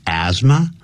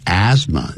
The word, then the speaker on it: asthma: that you will get asthma or depression，你可能会得到所谓呢这个 get 就等于 suffer from 气喘叫 asthma，那个不要念成 asthma 啊，叫 th 不念 asthma，或者是 depression，或者是抑郁症，有人把它叫做忧郁症。